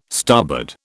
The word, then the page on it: starboard: British English